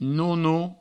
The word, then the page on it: nono: no-no